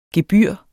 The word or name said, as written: gebyr